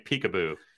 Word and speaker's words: PCAOB: And before anyone falls asleep, maybe we should start calling it Peekaboo, because that gets people's attention just right there. That's how you pronounce that acronym in inside the Beltway anyway.